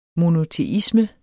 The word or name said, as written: monoteisme